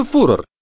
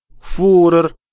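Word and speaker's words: Führer: «Кто такой Фюрер?» – спросил Бруно. – «Ты неправильно произносишь», – сказал отец и выговорил слово медленно и правильно, специально для того, чтобы сын повторил за ним. – «Фюрер», – повторил Бруно, стараясь в точности подражать отцу, увы, безуспешно.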